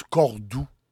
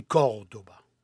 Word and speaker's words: Córdoba: En français, on prononce Cordoue. Moi, j'aime pas ça dire Cordoue. C'est Cordoba. Et on met l'accent sur le premier O, Cordoba.